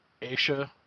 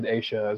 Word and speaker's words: Asha: So yeah, we're talking about, I heard a bunch of people say it, and I'm saying it Asha. – That's how I heard it as well. Okay. I saw like a pronunciation thing. – Yeah. – And then I also watched a little 20-minute video that the FBI put out, and they said Asha as well.